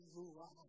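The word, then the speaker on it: ruaj (רוח): Así que la perfección de los santos, hermanos, es el ruaj, en hebreo, así es como se pronuncia, el ruaj de Dios, el aliento de Dios, el que viene a nosotros cuando lo recibimos.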